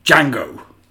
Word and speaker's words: Django: Django. – Django! – What my son says, the Django, just to annoy me. – Oh, yeah, okay. But you need to remind him the D is silent.